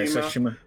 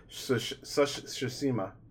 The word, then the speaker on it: Tsushima: Yeah, Tsushima. – Tsushima.